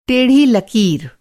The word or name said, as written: टेढ़ा